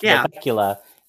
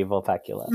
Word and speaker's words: Vulpecula: So if the constellation is pronounced Valpecula, then she has to be Valpecula.